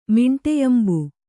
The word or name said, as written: ಮಿಂಟೆಯಂಬು